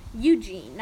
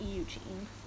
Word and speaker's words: Eugene: Eugene, not Eugene. Pronounce it like you're surprised. Like, Eugene?